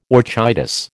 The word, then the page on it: orchitis: American English